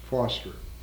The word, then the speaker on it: Vorster: Prime Minister Foster, V-O-R-S-T-E-R, pronounced Foster.